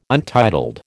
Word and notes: untitled: American English

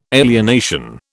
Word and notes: alienation: British English

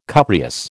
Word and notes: cupreous: American English

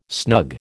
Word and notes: snug: American English

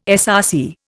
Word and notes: SRC: Indian

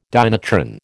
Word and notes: dynatron: American English

